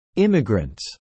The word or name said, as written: immigrants